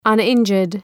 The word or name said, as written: uninjured